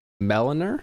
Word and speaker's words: milliner: Milliner? Like Melanie? – Mill. Like mill. Milliner.